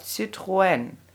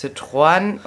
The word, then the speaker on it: Citroën: Und die zweisilbige Aussprache nebeneinander stehender, jetzt hätte ich fast Vulkane gesagt, Vokale, zum Beispiel wie in dem Wort Citroën.